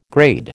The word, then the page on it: grade: American English